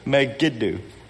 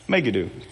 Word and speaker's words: Megiddo: Megiddo. How do you pronounce that? Megiddo.